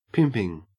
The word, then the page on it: pimping: Australian English